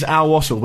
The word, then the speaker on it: Al Wasl: Now, I did seek advice on how to pronounce it, and apparently it is Al Wasl, but apologies if that's wrong.